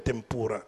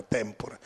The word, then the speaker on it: tempura: Eh, sapete perché il tempura, non si dice tempura come diciamo in Italia, ma tempura, in giapponese si chiama così?